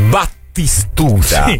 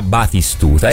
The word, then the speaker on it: Batistuta: Perché Battistuta non è mai stato Battistuta, era Batistuta.